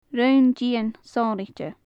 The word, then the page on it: roinn dion sonraichte: gaelic